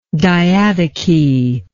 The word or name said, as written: diadochy